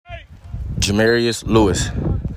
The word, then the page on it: Jermarius Lewis: juh MARE EE iss LEW iss